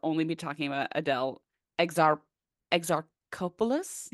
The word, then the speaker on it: Exarchopoulos: How do you pronounce it? – So I actually went on YouTube, like how to pronounce her name. – Yeah. – They gave me Exarchopoulos. – Exarchopoulos. – Yes.